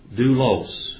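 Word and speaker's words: Doulos (δοῦλος): Doulos.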